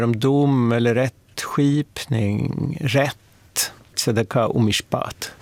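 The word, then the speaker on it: Tzedakah (צדקה): Tzedakah? – Ja, Anneli kanske vill uttala det lite bättre än vad jag gör.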